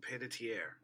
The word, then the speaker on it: Panettiere: I don't know how to say her last name. Panettiere? – Hayden Panettiere. I'm pretty sure it's Hayden Panettiere.